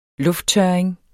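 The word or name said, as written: lufttørring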